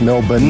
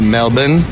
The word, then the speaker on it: Melbourne: Pronounced Melbourne. – Not Melbourne. – Melbourne. – Melbourne. – There you go. – There Melbourne. – You go. Yeah. – Melbourne. – Like Cleveland.